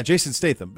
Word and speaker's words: Jason Statham: Am I saying his name right? Jason Statham? – Yeah, Jason Statham.